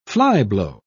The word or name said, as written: flyblow